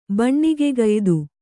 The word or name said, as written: ಬಣ್ಣಿಗೆಗಯ್ದು